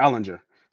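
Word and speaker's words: Ehlinger: I forgot how you say his name. Where is it? Where is he at? Is it Ellinger? Yeah, Ellinger. Sam Ellinger.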